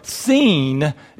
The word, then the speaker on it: Sin: But this is the wilderness of sin, or the wilderness of seen, if we want to say it like they would have said it.